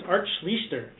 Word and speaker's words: Schlichter: We had Ben Bennett at number eight, Mike Perez at number nine, and Art Schlichter at number 10. Am I saying that correctly? – I believe it's Art Schlichter.